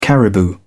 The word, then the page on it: caribou: British English